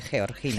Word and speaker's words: Georgina: Lo ha pronunciado como debe, Georgina. – Georgina, porque ella es de Jaca.